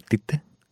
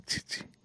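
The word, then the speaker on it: Tite: Ο Τίτε, γιατί δεν το λένε Τσίτσι, που είναι σωστή προφορά το όνοματό του.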